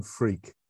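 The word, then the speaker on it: Freke: It's actually Tim Freak. – It is. – I know that's crazy, but that's actually, it's an old English name, and that's how it's pronounced.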